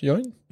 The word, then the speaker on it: Yeun: Yeun? – Ja, ja. – Steven Yeun, J.K. Simmons, Seth Rogen und Mark Hamill gemeinsam? Sie sprechen alle einen Charakter in der Serie Invincible. – Krass. Also, Steven Yeun hat bei Walking Dead mitgespielt.